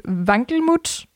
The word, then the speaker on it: Wankelmut: sure if I'm pronouncing this the right way, but Vankelmut? Yeah. Yeah. Okay.